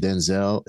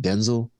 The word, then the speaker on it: Denzel: By the way, did you hear that his name is not pronounced Denzel? It's pronounced Denzel.